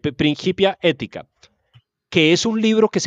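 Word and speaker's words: Principia Ethica: Usted que es un latinista, corríjame, ¿eso se dice principia ética o principia ética? – A mí, mi profesora de la Universidad de los Andes me dijo que la C en latín se pronuncia como Q, Principia ética.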